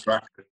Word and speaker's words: Straka: That's Straka.